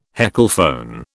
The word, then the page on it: heckelphone: British English